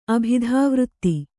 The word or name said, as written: ಅಭಿಧಾವೃತ್ತಿ